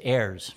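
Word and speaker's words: Ayres: I believe it's Ayers.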